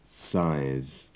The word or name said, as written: size